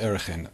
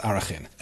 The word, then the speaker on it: Arachin: In today's Mishnah, we begin a new Masechta, Masechta Erechin, or probably more accurately pronounced Arachin.